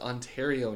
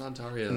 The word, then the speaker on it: Ontario: No, Ontario is pronounced Ontario, not Ontario.